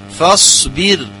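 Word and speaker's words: فاصبر: فصبر لحكم ربك، فص، فص، هكذا من نطق، ولا يجوز يعني ترقيقها، فصبر، فص، تفخيم لأن من حروف مستعلي، فص، فص، يعني كذلك فيه صفير، فصبر.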